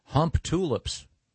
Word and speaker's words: Humptulips: In Grays Harbor County, Washington, there is a town called Hump Tulips. – Hmm. – I wonder if they pronounce, maybe it's pronounced Hump Tulips. – Boy, Hump-de-lips is almost more offensive. – Yeah. – But yeah, it's Humptulips.